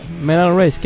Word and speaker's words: Medal Race: ¿O cómo se dice? – Medal Race, que es la etapa final de cada...